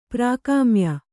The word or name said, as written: ಪ್ರಾಕಾಮ್ಯ